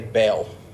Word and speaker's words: Baal: Now, I've heard it pronounced in many different ways, but the way that I pronounce it, maybe it's just me being Southern, I always say Baal. That's how I've always pronounced it.